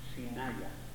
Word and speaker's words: Sinaia: así se escribe, S-I-N-A-I-A, Sinaya, el vapor Sinaya.